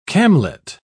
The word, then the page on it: camlet: British English